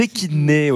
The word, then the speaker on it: échidnés: Les échidnés, oui.